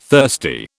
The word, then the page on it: thirsty: British English